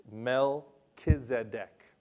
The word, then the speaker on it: Melchizedek: So the pronunciation of that is Melchizedek.